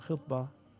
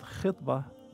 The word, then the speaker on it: khitbah: In Arabic, the term khitbah, kha, ta, ba, ta, marbuta, could be pronounced as khutbah. Khutbah means deliver a speech, like khutbah to Jum'ah or the Eid. Yeah. But khitbah, with a kasra or a zair beneath the first letter, it refers to the engagement.